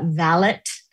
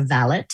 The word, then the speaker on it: valet: Charlie is the valet. That's how you pronounce that. I did not know. It's not like a car valet. It's a valet.